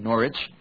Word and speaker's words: Norwich: The first one we know of is 1144 Norwich, England, which is pronounced, which is written like Norwich, but it's read Norwich.